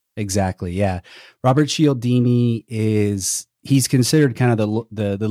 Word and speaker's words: Cialdini: From what I understand, this concept of psychological triggers is based on the work of Robert Cialdini.